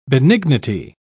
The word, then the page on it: benignity: British English